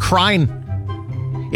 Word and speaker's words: crine: It's spelled like crime, but with an N. C-R-I-N-E. You know what crine is, right? That's just the word crying, but misspelled on purpose because that's how we say it.